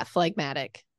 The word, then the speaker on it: phlegmatic: This adjective always refers to behavior, and it is pronounced differently, giving full weight to the G. How did you say it? Phlegmatic? – Yeah, phlegmatic.